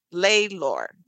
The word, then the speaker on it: Laylor: His name is Kevin Laylor. Did I say it right? – Yeah, it's like Taylor, but with an L.